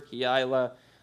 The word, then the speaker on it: Keilah: Keilah can also be pronounced Keilah. Fancy people will prefer Keilah. I'm not that fancy. You might hear me say Keilah a few times.